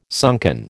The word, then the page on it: sunken: American English